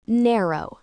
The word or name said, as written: narrow